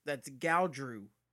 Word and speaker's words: Gaudreau: Johnny Gaudreau scored his 100th goal last night against the Bruins. Nick Ritchie. – I believe that's Gaudreau. You're saying it wrong. It's Gaudreau. Gaudreau.